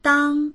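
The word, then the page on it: dang1: Mandarin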